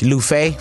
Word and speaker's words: Luffeigh: Last name is L U F F E I G H, Lufe. First F is silent.